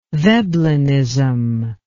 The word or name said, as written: veblenism